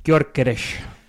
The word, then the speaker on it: Gyökeres: Gyökeres.